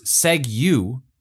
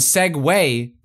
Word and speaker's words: segue: Segue, which was pronounced seg-you, and segue, pronounced segue.